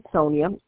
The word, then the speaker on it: Sonia: My first name is Sonia, S-O-N-I-A, and I'll spell the last name. It's S-H-A-H, Z as in zebra, A as in apple, D as in that.